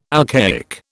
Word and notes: alcaic: British English

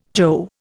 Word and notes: jo: Indian

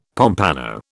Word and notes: pompano: British English

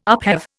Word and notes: upheave: Indian

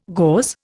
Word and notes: gauze: Indian

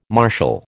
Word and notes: martial: American English

